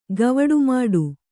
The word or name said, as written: ಗವಡುಮಾಡು